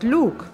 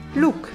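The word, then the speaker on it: luk: Das Wort Lug bedeutet Bogen, wenn es mit einem langen U gesprochen wird. Mit einem kurzen U ausgesprochen nimmt es die Bedeutung Zwiebel an. Look.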